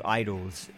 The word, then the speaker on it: IDLES: They're called Idols. It's spelled IDLES, not IDLOS.